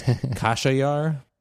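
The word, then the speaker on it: Khashayar: And then he said that it is a Persian name, but that it's not pronounced Xerxes in Farsi. That's the Greek pronunciation. And in Farsi, it's pronounced, and I'm sorry, Sarush, I will butcher this, Kashayar.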